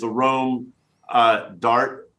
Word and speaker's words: Arôme d'Art: but it's it's uh, the website is a Rome Dart a r o m e d a r t dot com, mm-hmm.